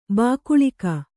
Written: ಬಾಕುಳಿಕ

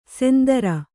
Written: ಸೆಂದರ

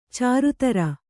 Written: ಚರಿತ್ರೆ

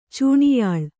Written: ಚೂಣಿಯಾಳ್

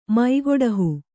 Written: ಮೈಗೊಡಹು

